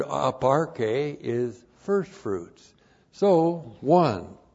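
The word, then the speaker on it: Aparche (ἀπαρχή): Aparche. Aparche. Looks like that in the Greek. A-P-A-R-C-H. Long E, up RK.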